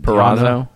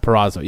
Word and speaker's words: Purrazzo: How you say it? – Purrazzo. – Purrazzo, yeah.